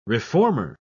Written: reformer

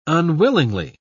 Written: unwillingly

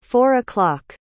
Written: four-o'clock

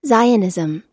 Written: zionism

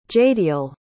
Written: jadiel

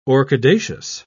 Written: orchidaceous

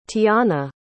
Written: tionna

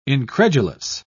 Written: incredulous

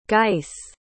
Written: geiss

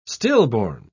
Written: stillborn

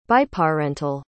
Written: biparental

